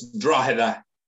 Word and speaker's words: Drogheda: But do you say Drogheda or Drogheda United? – It's Drogheda. – Drogheda. – Drogheda. – Okay, because I'm like a silent G.